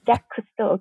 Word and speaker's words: Jacques Cousteau: It's often associated to Jacques Cousteau. I'm not sure if I'm pronouncing it right. Sorry. – Jacques Cousteau, okay.